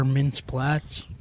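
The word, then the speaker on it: Mintz-Plasse: Christopher Mintz-Platz.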